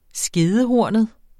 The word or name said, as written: skedehornet